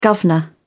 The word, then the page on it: guvnor: British English